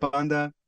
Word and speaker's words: PXNDX: Yeah, guys, if you don't know Panda, go check out Panda, PXNDX, that's how it's spelled.